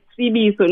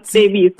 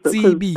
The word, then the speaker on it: Tsebiso: But also my name is Tsebiso, not Tsebiso. Tsebiso. A lot of Zulu people say Tsebiso.